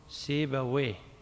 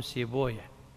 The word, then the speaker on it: سيبويه: اللغويون يقول راهويه المحدثون يقولون راهوية، اللغويون يقولون سيبويه، المحدثون يقولون سيبويه، اللغويون يقولون نفطويه، المحدثون يقولون نفطوية. ليش؟ لأنهم قرأوا في بعض الكتب أن من أسماء الشيطان ويه، فيكرهون نطق الاسم على اسمه فغيروه فخلوا راهوية سيبوية.